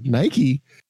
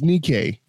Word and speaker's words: Nike: Now, when I saw that the first time, I was like, Nike? And they're like, no, it's Nike. Like, no, that says Nike. – No, it's Nike, but it's spelled Nike, N-I-K-E.